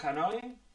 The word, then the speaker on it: Hanoi: Como decía, desde León con su nuevo disco, que se llama Hanoi, ¿no? Se pronuncia así, ¿no? Sí, no, Sí.